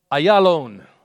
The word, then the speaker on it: Ayalon: And O moon, in the valley of Ayalon. Apparently that's how that's pronounced.